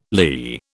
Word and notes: lee: British English

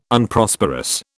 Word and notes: Unprosperous: British English

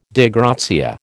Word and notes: Dei gratia: American English

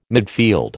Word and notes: midfield: American English

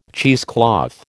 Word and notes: cheesecloth: American English

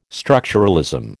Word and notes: structuralism: American English

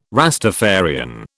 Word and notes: Rastafarian: British English

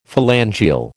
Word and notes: phalangeal: American English

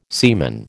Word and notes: semen: American English

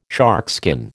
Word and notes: sharkskin: American English

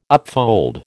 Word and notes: upfold: American English

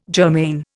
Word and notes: germane: Indian

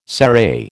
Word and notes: serai: American English

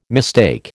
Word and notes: mistake: American English

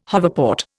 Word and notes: hoverport: Indian

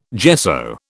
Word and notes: gesso: British English

